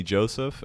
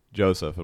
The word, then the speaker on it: Josef: So yeah, you can find me on Instagram or Facebook at the one and only Joseph, and that's spelled the number one and only Joseph, and my name is J-O-S-E-F.